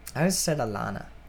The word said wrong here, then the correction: Elena: It's Elena. – How do you know it's Elena? Did you watch it? Because I know somebody whose name is spelled exactly like that, and she pronounces it Elena.